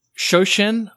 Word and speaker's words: Shoshin: Can you talk about Shoshin? I think that's the correct pronunciation.